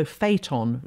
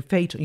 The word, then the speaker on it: Phaeton: The idea here, and then there's also Phaeton, Phaeton, you can Google Phaeton, which is spelt like Phaedra with the P-H-A-E, so Phaeton with T-O-N on the end.